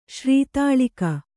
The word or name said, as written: ಶ್ರೀತಾಳಿಕ